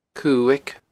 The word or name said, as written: Cuaig